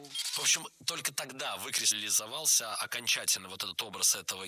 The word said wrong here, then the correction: выкристаллизовался: Было сказано «выкристаллизовался», но это неверно. Правильный вариант – «выкристаллизовался». Ударение падает на первый слог, на приставку «вы».